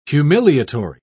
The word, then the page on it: humiliatory: British English